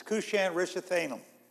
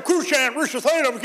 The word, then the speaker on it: Cushan-Rishathaim: I don't know how long it took me, days, weeks, months, but I finally learned how to pronounce this. Cushan-Rishathaim, king of Mesopotamia. Boy, I sound smart when I would say that. And he sold them over to the hand of Cushan-Rishathaim, king of Mesopotamia.